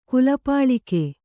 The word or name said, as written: ಕುಲಪಾಲಿಕೆ